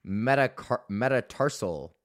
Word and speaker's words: metatarsal: I don't know if I'm saying that right. Is it metacarpal? Fifth metatarsal. Metatarsal? Oh, yeah, it's your metatarsal, not metacarpal.